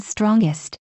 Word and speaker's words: strongest: These are the words stronger and strongest. Notice how I used a hard G sound when I said them.